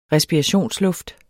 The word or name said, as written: respirationsluft